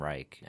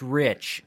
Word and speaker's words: Reich: My uncle started pronouncing our last name Reich, and I really respected my uncle, and he was a doctor of communications, I think, and really, really intelligent guy. So it was just something where people have been mispronouncing my name my entire life, and it was just easier for me to turn into it than to try and steer away. It's Rich, okay? – Is it the spelling just R-I-C-H? – R-E-I-C-H, just like the German Reich.